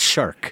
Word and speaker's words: Marchal: You have to say his name. It's Shark.